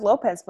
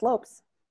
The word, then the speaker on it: Lopes: Everyone says Lopez, but it's Lopes. If you think about it. – Is that the Cape Verdean pronunciation? – Mm-hmm. L-O-P-E-S, yeah.